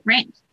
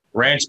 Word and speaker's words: Wranch: It's spelled WRANCH, so. – Ranch. – Ranch, but with a W, basically.